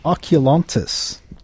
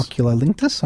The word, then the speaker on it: oculolinctus: Oculontis. Would you reckon that's correct pronunciation? – Oculinctus? Linctus? Lingual? – Oculolinctus? – Oculolinctus. All right, we'll go with that one.